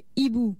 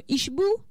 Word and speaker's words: hibou: C-H-E, accent circonflexe, N-E. Dernier mot, hibou. Comment on écrit hibou? Surtout les enfants, n'oubliez jamais le H de hibou. On peut pas dire un ishbou. Mais enfin, retenez que hibou s'écrit toujours avec un H. H-I-B-O-U.